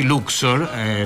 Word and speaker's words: Luxor: Noi quest'anno siamo a Luxor, gli americani pronunciano così Luxor, la città egiziana, appunto è dedicato all'antico Egitto.